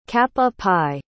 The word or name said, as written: cap-a-pie